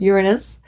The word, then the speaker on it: Uranus: But the one I want to talk about here is the planet Uranus. That's how I pronounce it. I know there's many different pronunciations, or a few different pronunciations, but Uranus, the planet of liberation, of freedom, of revolution, of radical change, of shift of assembly point.